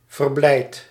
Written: joyeux

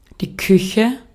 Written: cucina

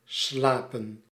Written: slapen

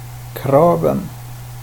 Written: kraven

